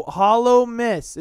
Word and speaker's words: Hallowmas: It's no, Hallowmas. It's spelt like Christmas, but hollow.